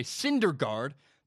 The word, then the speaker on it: Syndergaard: And by the way, Syndergaard, because I pronounced it wrong in the last video and people got really mad at me.